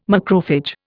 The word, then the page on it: macrophage: Indian